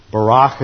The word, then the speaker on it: Barak: And what happens by the time we get to Deborah in the third cycle, she goes to Barak, Barak as it's pronounced in Hebrew.